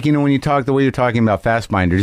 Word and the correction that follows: Fassbender: does he pronounce it Fassbender or Fassbinder? – Fassbender. – Fassbender.